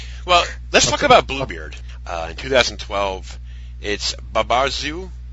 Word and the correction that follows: Barbazul: Is that how it's pronounced? – Barbazul. – Barbazul, okay.